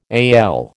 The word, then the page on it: AL: American English